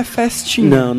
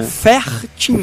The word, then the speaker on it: Fertinha: Não, você não, eu não estou falando errado. – Não é festinha. – Não é festinha. – Fertinha. – Fertinha, com R.